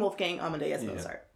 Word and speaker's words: Wolfgang Amadeus Mozart: I know the name Wolfgang Amadeus Mozart.